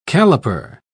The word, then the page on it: caliper: British English